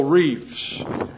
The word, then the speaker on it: Reff: And so I want to make a tie-in here because his name is James R-E-F-F. How would you pronounce that? No, his name is James Reef.